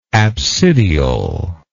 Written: apsidiole

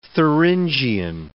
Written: Thuringian

